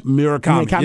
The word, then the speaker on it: Murakami: Munetaka Murakami? – Murakami, yeah.